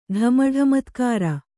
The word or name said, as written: ಢಮಢಮತ್ಕಾರ